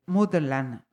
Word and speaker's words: Mudland: Él es compositor, profesor de guitarra y además es la mitad de Mutterland, lo pronuncio bien. Sí, correcto.